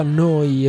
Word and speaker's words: Neu: Si scrive Neu, si pronuncia Noi e vuol dire nuova.